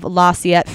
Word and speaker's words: La Salette: In 1846, she appeared to the children of La Salette, France. – Yes. – Did I pronounce that right? – Yes.